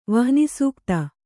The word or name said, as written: ವಹ್ನಿಸೂಕ್ತ